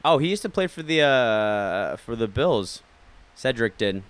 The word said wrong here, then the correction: Shedrick: Shedrick. – Mm-hmm. Am I saying it wrong? – Yeah, you're saying Cedric. It's Shedrick. – Shedrick. – Like Shed and then Rick.